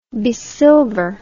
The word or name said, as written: besilver